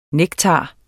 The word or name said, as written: nektar